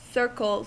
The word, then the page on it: circles: American English